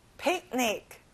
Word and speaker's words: picnic: 소풍, picnic. 친구들 가운데 C 발음은 잘 안 나요.